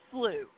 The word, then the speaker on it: Slough: Let's start with something called the 6 Mile Cypress Sloth. I hope I got that right. Preserve. Doesn't it have boardwalks for people and swamps for alligators? – Well, it is. It's pronounced slough.